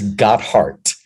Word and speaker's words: Gotthardt: So why don't you go ahead and tell us how your last name is pronounced? – It's Gotthardt. – Gotthardt. – Kind of like got and heart at the end of it.